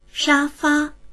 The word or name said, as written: sha1fa1